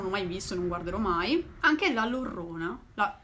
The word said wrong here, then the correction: La Llorona: La Llorona.